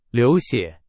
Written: 血

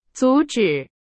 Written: 阻止

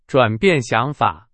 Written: 转变

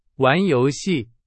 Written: 游戏

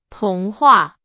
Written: 童话

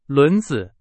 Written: 轮子

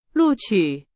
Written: 录取

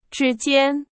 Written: 之间

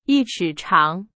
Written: 尺

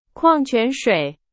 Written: 矿泉水

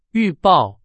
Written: 预报

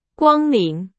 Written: 光临

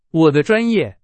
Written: 专业

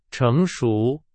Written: 成熟

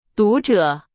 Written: 读者

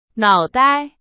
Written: 脑袋

